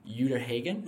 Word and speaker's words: Uta Hagen: You also study under, I'm going to butcher the name, Uta Hagen.